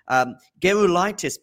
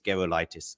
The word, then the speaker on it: Gerulaitis: Gerulaitis, by the way, it's a hard G because that name is, I think, Lithuanian, of Lithuanian descent, and a Lithuanian friend of mine said it's Gerulaitis.